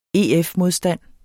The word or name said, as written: EF-modstand